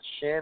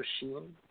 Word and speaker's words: Shinsuke: If you say Shin instead of Sheen, the pronunciation says, I don't know you, but I have respect. Shinsuke Nakamura.